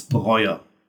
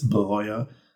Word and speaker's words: Breuer: It's Breuer. – Breuer? – Yeah, as in B-R-O-Y-E-R, but it's a German R, so it's not Breuer, it's Breuer. But just Breuer.